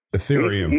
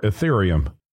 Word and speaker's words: Ethereum: It's the one that begins with an E that I can't pronounce. Can you? – Ethereum. – Ether? – Ethereum. Yes. – Okay. – Ethereum. Correct. Yeah.